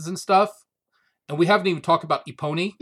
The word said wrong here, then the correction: Epona: who is the It's not really how you pronounce her, right? – Is it not Epona? Oh, it's Epona. – Yeah, not a pony.